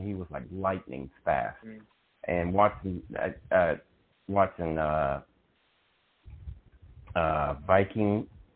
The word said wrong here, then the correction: Vikingo: how do you pronounce it? Viking? – Yeah, Vikingo. – Vikingo, yeah. Vikingo.